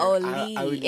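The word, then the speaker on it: Auli'i: Oli. It's like A-U-L-I apostrophe I.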